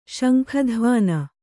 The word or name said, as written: ಶಂಖಧ್ವಾನ